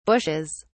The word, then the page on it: bushes: British English